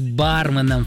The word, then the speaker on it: барменом: Несмотря на ожесточенные споры вокруг данного слова, лингвисты утверждают, что верным является только один вариант с первым ударным слогом. Тем более, что заимствовано оно из английского, где тоже произносится с акцентом на букву А. Короче говоря, правильно говорить барменом, Фаина.